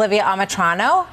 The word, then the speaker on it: Olivia Amitrano: Olivia Amatrano, is that the way you pronounce it? – Yes.